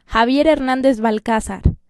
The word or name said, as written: Javier Hernandez Balcazar